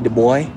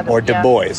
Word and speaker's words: Du Bois: I said Abby DuBois, and she always snickered at me because I wasn't cultured enough to know it was a French name, and it's pronounced different.